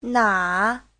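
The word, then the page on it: 哪: na3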